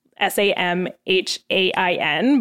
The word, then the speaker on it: Samhain: And if you're reading, if you're going to try to Google that, it's it's spelt Samhain, S A M H A I N, but it's pronounced Samhain. And I know that because I Googled it and practiced it.